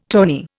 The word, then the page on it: tawny: Indian